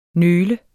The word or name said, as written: nøl